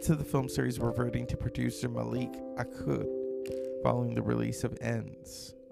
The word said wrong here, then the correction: Akkad: What is his name? Let me say it properly. Malik Akkad.